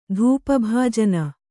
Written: ಧೂಪಭಾಜನ

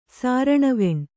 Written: ಸಾರಣವೆಣ್

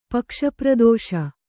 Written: ಪಕ್ಷಪ್ರದೋಷ

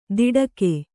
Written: ದಿಡಕೆ

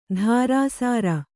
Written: ಧಾರಾಸಾರ

